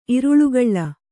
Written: ಇರುಳುಗಳ್ಳ

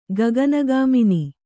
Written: ಗಗನಗಾಮಿನಿ

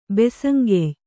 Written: ಬಸಂಗೆಯ್

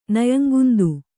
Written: ನಯಂಗುಂದು

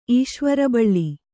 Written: ಈಶ್ವರಬಳ್ಳಿ

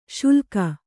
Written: ಶುಲ್ಕ

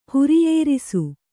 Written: ಹಿರಿಯರಸಿ

